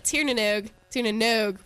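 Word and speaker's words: Tír na nÓg: And both of these bands, plus Baron Grays, will be playing at Local Band Local Beer tonight off Blount/Blount Street at Tiernanogue with a long O.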